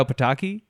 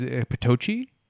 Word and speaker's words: Pataki: And I had a friend named Renato Pataki, and the teacher mispronounced his name as Patochi. And he, like, kind of cockily was like, it's Pataki.